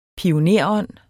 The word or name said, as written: pionerånd